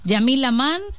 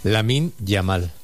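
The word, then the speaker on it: Lamine Yamal: Dice, todavía no sé si es Yamil Lamán, Lamin Yamal o Yamin Yamal. O sea, ese podría ser tú, ¿no? ¿Cómo se dice, Luis? – Lamin Yamal. – Ah, bueno, muy bien.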